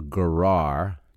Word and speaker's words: gerah: Two marks for a gerah, G-E-R-A-H, gerah. I'm sorry, gera is how you pronounce that.